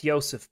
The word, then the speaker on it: Jozef: The just so we pronounce Mr. Puska's name, it's the interpreter said Jozef, is it? Jozef? – Yeah, it's Jozef Puska, yeah.